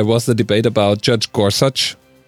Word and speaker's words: Gorsuch: He said, "It's Gorsuch."